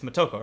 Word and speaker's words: Motoko: But the character designer for the movie, Hiroyuki Okura, I don't fucking know how to pronounce that, he wanted Motoko, I can't always mix it up, it's Motoko, right? – Yes.